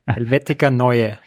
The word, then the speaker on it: Helvetica Neue: Which can you pronounce that, for us? – Helvetica Neue. – Neue. – Neue. – Tom wasn't off.